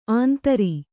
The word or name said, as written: ಆಂಧ್ರ